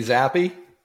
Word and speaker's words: Zappe: Bailey Zappi? – Yeah.